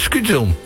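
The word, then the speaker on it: skûtsjesilen: Skutjesielen. Ik kan het niet goed helemaal lekker uitspreken, merk ik. – Skutjesielen. Skutjesielen. – Skutjesielen. – Nou, eigenlijk moet je het een beetje gewoon laten gebeuren. Skutjesielen.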